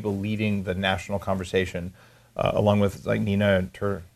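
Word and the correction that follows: Teicholz: I would say her name wrong. – Ty Schultz.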